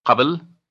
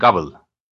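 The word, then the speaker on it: قبل: In some words, like the one for before, it can be both Qabl and Gabl.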